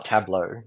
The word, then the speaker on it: tabla: But did I pronounce it properly? Tableau? – Yeah, tableau. T-A-B-L-A.